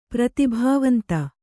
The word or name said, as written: ಪ್ರತಿಭಾವಂತೆ